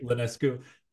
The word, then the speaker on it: Ionescu: The only successful and fun part of All-Star weekend was Steph Curry versus Sabrina Ionescu. Did I say her last name right? – I think you're pronouncing it correctly. – Ionescu.